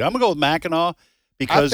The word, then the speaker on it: Mackinac: I don't think that's the name of it, but go ahead. – Am I mispronouncing it? Yeah, you are. – Oh, what? – I think it's Mackinac. – Mackinac? – Yeah, I think. Okay. How's it spelled? – M-A-C-K-I-N-A-C. – I don't know.